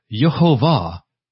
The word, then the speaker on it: Yehovah: Well, much to the contrary, Yehovah is the name of God. And I'll show you that. Now, Yahweh, there's no real scriptural support or historical support for this pronunciation, but there is for Yehovah.